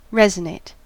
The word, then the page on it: resonate: American English